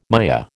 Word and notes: Maya: American English

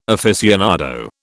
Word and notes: aficionado: British English

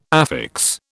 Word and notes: affix: British English

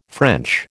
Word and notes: French: American English

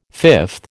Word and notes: fifth: American English

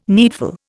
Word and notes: needful: Indian

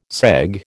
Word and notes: segue: American English

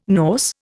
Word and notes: Norse: Indian

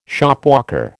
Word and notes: Shopwalker: American English

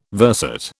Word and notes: verset: British English

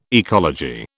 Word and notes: ecology: British English

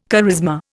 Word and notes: charisma: Indian

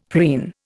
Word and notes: preen: Indian

